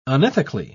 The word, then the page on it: unethically: British English